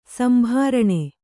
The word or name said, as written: ಸಂಭಾರಣೆ